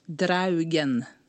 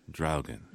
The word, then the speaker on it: Draugen: Not dragon, because if I'm the English word is dragon, but this word is? – Draugen. – Draugen.